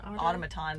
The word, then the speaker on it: automaton: they ended up replacing it with kind of a ottoman hand. Is that how you pronounce that? – Like an automaton. There we go.